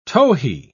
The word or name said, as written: towhee